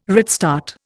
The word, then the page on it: redstart: Indian